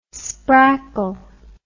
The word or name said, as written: sprackle